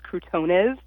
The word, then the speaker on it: croutones: And I'm going to make sure I say this correctly. It's croutones.